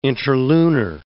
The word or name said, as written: interlunar